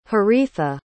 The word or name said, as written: haritha